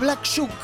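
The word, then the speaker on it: Black Shuck: Pero la importancia de lo que nos compete es el tema mismo, que era Black Jack, que significa, se escribe S-H-U-C-K. – Claro, no es como Black Jack, como el juego de cartas. – Claro, no, este es Black Shook.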